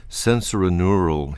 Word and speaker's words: sensorineural: So you suffer from sensorineural hearing loss, if I pronounced it correctly. Could you explain what that is? – Sure. You did pronounce it correctly.